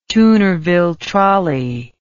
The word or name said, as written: toonerville trolley